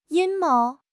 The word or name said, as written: 阴谋